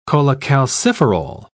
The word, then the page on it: cholecalciferol: British English